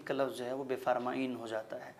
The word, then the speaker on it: بفرمائید: تو ایسا نہیں ہے کہ یہ کوئی دوسرا لفظ ہے, بلکہ اس کا تحریری وجود بفرمائید ہے, مگر تلفظ اور ادائیگی کے اعتبار سے وہی لفظ بفرمائین ہو جاتا ہے.